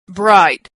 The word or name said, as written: bright